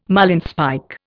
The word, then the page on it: marlinspike: Indian